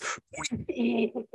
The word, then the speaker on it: Puig: Someone mentioned Yasiel Puig, but you mispronounced it. It's not Puig, it's Puig.